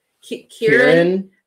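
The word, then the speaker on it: Ciarán: Siren? – Sure. Kieran. I'm gonna say C's usually make a K sound.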